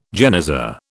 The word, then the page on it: genizah: British English